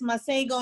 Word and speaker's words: Masego: I actually enjoyed, I think his name is pronounced Masiho. – Oh, yes, I love that song. – Is that his thing, how you pronounce his name? – Yes, Masego, and he plays the sax.